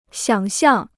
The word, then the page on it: 想象: xiǎng xiàng